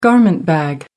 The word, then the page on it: garment bag: American English